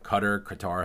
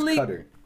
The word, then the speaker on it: Qatar: Qatar, however you want to pronounce it. They are the only... I thought it was Qatar.